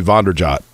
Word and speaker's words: Vanderjagt: Mike Vanderjagt, for example, when he first got to town, Mike Vanderjagt was like, well, my last name is actually Vonderjot. Vonderjot is how I pronounce it.